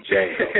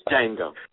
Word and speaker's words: Django: I think this is NFL football's version of hiring, I'm calling him Antony, not D'Antoni, because the D is silent, like Django. – Nice. – It's Django.